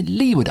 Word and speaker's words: leeward: They're known as the Leeward Islands because they're on the lee side, away from the wind. All right, if you're a sailor, you call it leeward, even if you still spell it L-E-E-W-A-R-D.